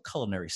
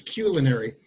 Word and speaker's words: culinary: I just love the banter between Neelix and Paris when you're talking about, of course, it might not meet your personal culinary standards, Neelix says. I pronounced that wrong. Yes. It's culinary.